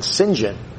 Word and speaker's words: St. John: It looks like Harold St. John, but you pronounce it, I understand, St. John.